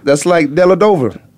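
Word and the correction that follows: Dellavedova: Della Vadova. It's a V. – I know. I'm saying that's exactly why LeBron learned how to say his name. Went home and practiced in the mirror. Della Vadova.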